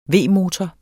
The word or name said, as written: V-motor